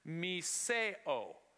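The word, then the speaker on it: miseo (μισέω): And it is pronounced miseo. Miseo.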